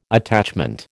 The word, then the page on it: attachment: American English